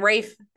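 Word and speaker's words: Ralph: When Rafe, I said it right.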